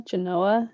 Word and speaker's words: Genoa: Genoa. It's Genoa in like the pronunciation.